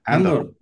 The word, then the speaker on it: Andor: sale en Endor. ¿Andor o Endor? ¿Cómo se dice? – Andor.